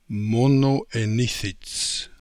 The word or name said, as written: Monoenithits